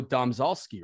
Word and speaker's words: Domzalski: Domzalski, right? – Domzalski, you nailed it.